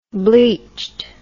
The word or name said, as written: bleached